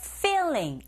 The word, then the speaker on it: feeling: feeling. 앞글자 소리 제대로 내시면 되죠. 필링 아니에요, 여러분. 필링하게 되면 의미가 완전히 변합니다.